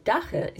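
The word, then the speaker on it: dagga: Anyway, dacha is actually the Afrikaans pronunciation.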